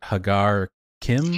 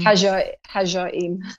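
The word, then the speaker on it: Ħaġar Qim: How do you pronounce this now? Is it Hagar Kim? – Hajarim. – Hajarim. Okay. Yeah.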